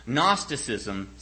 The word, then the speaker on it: Gnosticism: Gnosticism, that's spelled with a G in the front for some reason, that silent G.